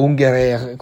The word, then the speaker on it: Ungerer: Curiosamente sono queste anche le tematiche di un altro grande illustratore e grafico del secolo scorso, che è lo strasburghese Tommy Ungerer, o Ungerer come si pronuncia in francese, che si era occupato di tematiche affini.